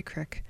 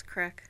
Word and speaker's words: creek: And you said it right. You said creek. I always say crick. – My dad says crick.